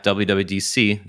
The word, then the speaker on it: WWDC: But at the same time, I know a lot of developers, and I talked to a bunch of them at WWDC. That's the first time I've said that correctly today, that were very disappointed that it only went so far as differential privacy.